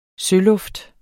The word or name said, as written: søluft